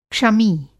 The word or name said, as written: क्षमी